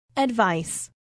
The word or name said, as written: advice